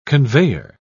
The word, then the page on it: conveyer: British English